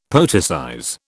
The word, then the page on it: poeticize: British English